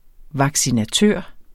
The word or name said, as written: vaccinatør